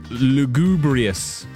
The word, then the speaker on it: lugubrious: Best word for Tony in Eastbourne is lugubrious, which I think has a hard G. I think it's lugubrious. It's not lugubrious. Nah, it's lugubrious. It's definitely lugubrious. Someone else texting in, Emil, surely the most beautiful word must be moist.